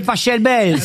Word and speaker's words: Pachelbel: Cannelloni, c'est Pachelbel. – S'il est Allemand, ça se dit Pachelbel.